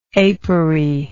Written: apery

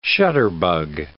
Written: shutterbug

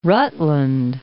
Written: Rutland

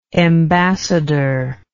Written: embassador

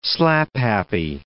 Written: slaphappy

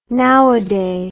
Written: nowaday

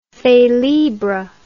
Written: felibre

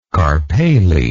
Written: carpale

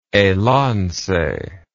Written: Elance

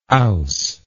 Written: owse

